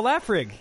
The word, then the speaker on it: Laphroaig: I only laugh at Laphroaig because I've mispronounced it most of my adult life, but it's Laphroaig actually. Laphroaig.